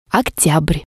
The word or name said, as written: oktyabr